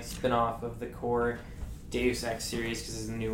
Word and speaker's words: Deus Ex: So they just dropped Deus Ex, that's how you say it, right? – Deus, yeah.